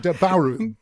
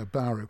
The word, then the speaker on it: Bauru: which is a little village just uh, just just outside Bauru. B B A U R U, which I, produce, I presume is pronounced uh, Baru.